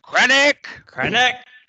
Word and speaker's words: Krennic: Krennic.